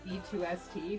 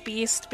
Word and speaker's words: B2ST: You know, I mentally pronounced it B2ST for the longest time. – That's fair. I only learned it was Beast because of the concert they had with Shiny 2PM 4-Minute Girls' Generation TVXQ.